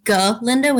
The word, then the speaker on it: Galinda: One important note is that from the beginning of the show, the good witch, Glinda, is referred to as Galinda, and she's very particular about the pronunciation of her name Galinda with a G-A instead of just a G.